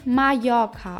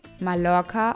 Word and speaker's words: Mallorca: Der doppelte LL wird Ja ausgesprochen. Wie Mallorca auf Spanisch. Mallorca auf Deutsch.